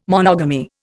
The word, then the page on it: monogamy: Indian